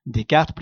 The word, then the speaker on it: Descartes: Descartes, plus exactement. On ne va pas prononcer les S dans le midi.